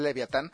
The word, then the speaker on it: Leviatán: Se llama Leviatán, pronunciado literal en español Leviatán.